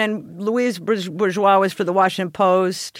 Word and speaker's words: Bourgeois: Is that how you say her name? – Yeah, Louise Bourgeois.